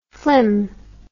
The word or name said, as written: flim